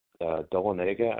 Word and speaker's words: Dahlonega: It's Dahlonega, I believe, is how it's pronounced.